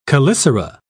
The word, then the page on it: chelicera: British English